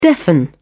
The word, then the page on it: deafen: British English